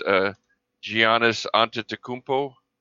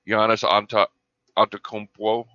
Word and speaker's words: Giannis Antetokounmpo: His name is, if you just pronounced it the way it looks, it's Giannis Antetokounmpo. But the actual name is Giannis Antetokounmpo or something like that.